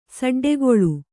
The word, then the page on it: ಸಡ್ಡುಗೊಳ್ಳು: saḍḍugoḷḷu; Kannada